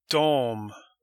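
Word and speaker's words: de: Dom. They. Dom. This is pronounced dom, but it's spelled D-E.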